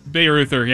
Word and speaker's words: Bayreuther: Gavin Bayreuther. – Bayreuther, yeah.